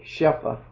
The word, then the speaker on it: Shefa: S-H-E-F-A, Shepha.